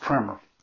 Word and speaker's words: primer: You know, some books in the old days were called primers, but it's pronounced primer. Everywhere I studied it said it's pronounced primer with a short I.